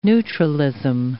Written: neutralism

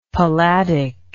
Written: palladic

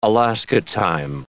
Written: Alaska time

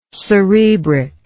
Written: cerebric

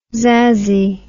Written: zazzy

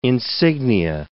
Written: insignia

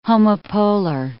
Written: homopolar

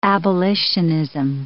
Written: abolitionism

